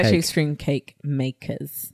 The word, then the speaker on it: Extreme Cake Makers: So, and I can't imagine also being featured in Extreme Cake Makeovers. Am I saying that right? – Actually Extreme Cake Makers.